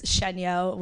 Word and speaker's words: Shenyo: But my last name is Shenyo, which is French. But if you saw it spelled, people just assume that it's Asian for the fact of, like, the pronunciation of it.